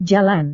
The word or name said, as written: jalan